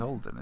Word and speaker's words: Alden: No, it's Alden, isn't it? – Alden Aidenreich, yeah. – Alden, and the guy who played Han Solo.